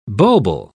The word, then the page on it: bulbil: British English